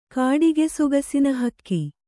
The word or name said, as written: ಕಾಡಿಗೆಸೊಗಸಿನಹಕ್ಕಿ